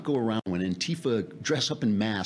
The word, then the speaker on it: Antifa: this thing with Antifa, Antifa. Now we got to put the accent on the T.